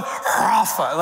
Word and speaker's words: Rapha: But to say it right, if you want to do it with some authenticity, you got to like conjure up some spit in there. That it's Jehovah Rapha.